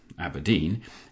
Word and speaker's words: abrdn: After Aberdeen became ABRDN, lowercase letters pronounced Aberdeen, in April, I thought we'd seen the worst rebrand of the year.